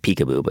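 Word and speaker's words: Picaboo: Like Peekaboo, but spelled like Pick, P-I-C.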